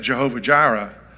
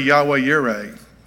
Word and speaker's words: Yahweh Yireh: And there's even a song that said Jehovah Jireh. And uh, it's really Yahweh Yireh. That's the way you should pronounce it.